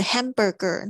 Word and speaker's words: hamburger: 那个有很多同学 hamburger 那个 ger 就念不出来了，所以这个是我比较听到常常会有的发音的状况。 hamburger 就重音是在 ham 上面，当你要讲 hamburger 的时候，hamburger。